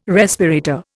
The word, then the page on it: respirator: Indian